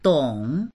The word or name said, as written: dong3